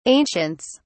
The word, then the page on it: ancients: British English